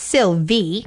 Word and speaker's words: Silwy: So the Sylvay Company, it's spelled S-I-L-W-Y, but it's pronounced Sylvie because W's are V's in the German language.